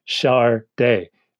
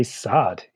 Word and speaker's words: Sade: but Sade's record company actually had to put a thing on the cover of Diamond Life pronounced Chardet because you know if everybody would just say Sod, you know, like which was a very 1985 kind of reference to make, but you know.